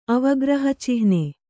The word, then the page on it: ಅವಗ್ರಹಚಿಹ್ನೆ: avagrahacihne